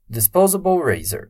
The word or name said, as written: disposable razor